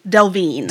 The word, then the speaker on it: Delvene: Delvene.